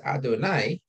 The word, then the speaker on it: Adonai: one says Adonai, which is the way we pronounce Hashem's name, the Yud Kei Vav Kei.